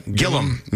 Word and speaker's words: Gillam: Yesterday, the RCMP says they have reason to believe the pair was in the Gillam, am I saying that right? – Gillam, Manitoba area.